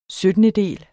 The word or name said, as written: syttendedel